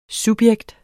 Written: subjekt

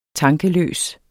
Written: tankeløs

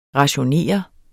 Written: rationere

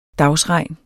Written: dagsregn

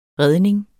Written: redning

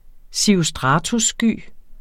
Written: cirrostratussky

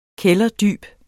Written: kælderdyb

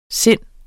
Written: sindet

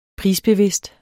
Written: prisbevidst

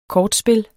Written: kortspil

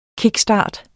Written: KICKSTART

